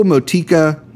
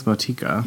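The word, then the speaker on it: Motueka: It's M-O-T-U-E-K-A. Triple. – I think that's how you say it. – Motika. – I think it's Motika. – Motika. Yeah. So that's how I've heard it said.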